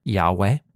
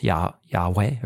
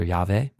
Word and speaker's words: Yahweh: preserved a pronunciation of the name Yahweh, but also in some Greek manuscripts that are pre-Christian, it's pronounced Yahweh or Yahweh.